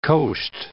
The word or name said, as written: coast